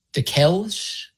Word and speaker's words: Dickel's: We've got the Dickel's Texas Two-Step Tacos. D-I-C-K-E-L. You think I'm pronouncing that right? Dickel's? – Dickel's is good.